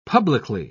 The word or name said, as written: publicly